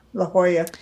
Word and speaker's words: La Jolla: So he moved his family to La Jolla. Is that how you say it? – La Jolla. Yeah, La Jolla.